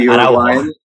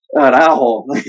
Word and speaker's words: Araujo: So at this point, you know, we're expecting Christensen to come in, you know, and we have, correct me if I'm wrong on this pronunciation, but Araujo. – Yeah, Araujo. – Araujo.